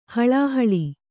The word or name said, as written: ಹಳಹಳಿ